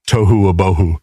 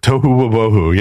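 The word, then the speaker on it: tohu abohu (תֹהוּ וָבֹהוּ): In Hebrew, it's tohu abohu. – tohu abohu. – Yeah, it rhymes and and it means basically it's formless or chaotic.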